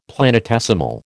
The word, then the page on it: planetesimal: American English